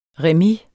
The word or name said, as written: remis